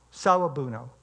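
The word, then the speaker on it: Sawubona: It's pronounced Sawabuno. Sawabuno.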